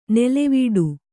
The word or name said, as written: ನೆಲೆವೀಡು